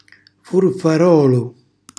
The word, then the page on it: furfarolu: Calabrese